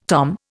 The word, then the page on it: tom: Indian